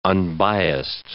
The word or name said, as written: unbiased